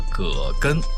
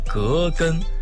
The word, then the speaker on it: 葛根: 我们身边有很多朋友都把葛根读作葛根，姓葛的那个葛。其实啊，这个药的正确读音为葛根，瓜葛的那个葛。